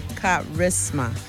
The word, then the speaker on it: charisma: C H A R I S M A. And that's correct. Well done. That H is silent, but it's